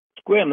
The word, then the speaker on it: Sequim: Squim, that's like the sequim that CJ has a hard time pronouncing.